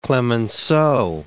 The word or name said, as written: Clemenceau